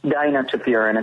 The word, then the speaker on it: dinotefuran: Dinotefuran, is that how you pronounce it, or dinotefuran? – Dinotefuran is how I pronounce it, but you're potato, potato.